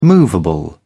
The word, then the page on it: movable: British English